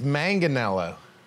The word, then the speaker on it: Manganiello: Well, it's Manganiello. Manga. Yeah, I mean, Don Air and McKees Rocks, they do the soft G, but up in Mount Lebanon. – Not me, dude. – It's a hard G.